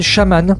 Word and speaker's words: SHMN: Il s'appelle Shaman. Shaman ici s'écrit sans voyelle. S-H-M-N.